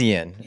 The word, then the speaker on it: Eoin: We have one more legitimate tweet, and this comes from the name is spelled E-O-I-N, so how would you pronounce that? – Ian? – Maybe that is Ian. E-O. Interesting. Sanford. Ian Sanford. We're going to call him Ian.